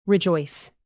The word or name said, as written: rejoice